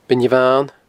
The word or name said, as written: Beinn Bhan